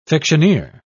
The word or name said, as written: fictioneer